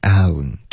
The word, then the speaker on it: down: 对，所以 I turned down，那针对发音大家需要注意 down， OK，是一个双元音 down， turn down， OK。